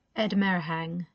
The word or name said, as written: Edmer Heng